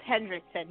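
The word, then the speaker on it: Henriksen: Lance Heck, how's his last name pronounced again? Lance Hendrickson? Is that it? – Lance Hendrickson, yes.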